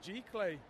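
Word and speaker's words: giclée: It's just G-clay. A G-clay print. G-clay.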